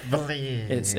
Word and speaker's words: valise: Yeah, so the first valise that you come across is valise, but anyway. – Okay. Is inscripted. – Actually, it's pronounced valise.